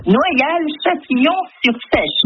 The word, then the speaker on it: Noyal-Châtillon-sur-Seiche: Je laisse le prononcer parce que c'est compliqué pour moi. – Noyal-Châtillon-sur-Seiche. – Noyal-Châtillon.